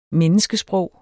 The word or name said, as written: menneskesprog